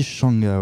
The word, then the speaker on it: Mchanga: Eh, je crois qu'en fait, on dit Shanga, ouais. Genre, ah ouais, euh, on prononce pas le M.